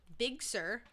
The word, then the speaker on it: Big Sur: Anyways, they introduced macOS Big Sur. – What is that? It just sounds weird. – It's spelled, it's not, macOS Big, B-I-G, S-U-R. Big Sur.